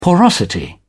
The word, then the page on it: porosity: British English